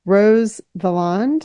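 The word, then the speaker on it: Valland: So Speaking of characters, can we talk about someone that was a real life person who's very important to this novel, Rose Villand? – Oh, yeah. – Is that how you say her name? – Yes, yeah.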